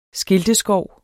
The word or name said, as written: skilteskov